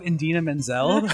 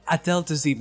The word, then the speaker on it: Idina Menzel: Considering he managed to fuck up Idina Menzel. – Oh, right. What about that? – I love that. Three syllables, sir. – Because he tried so hard because he was really enunciating the wickedly talented Adele Dazeem.